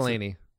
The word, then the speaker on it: McElhenney: How do you pronounce McElhenney? – McElhenney.